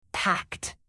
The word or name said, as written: packed